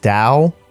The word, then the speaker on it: Tao: But then I realized that T-A-O is pronounced Dow, and her name is straight up Dow Jones, like the stock exchange.